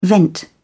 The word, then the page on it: vent: British English